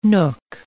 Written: nook

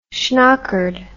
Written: schnockered

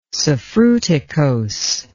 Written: suffruticose

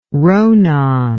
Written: ronan

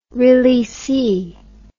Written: releasee

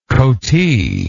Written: coatee

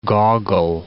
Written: Gogol

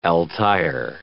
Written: Altair

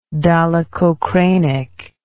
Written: dolichocranic

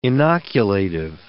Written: inoculative

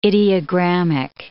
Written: ideogrammic

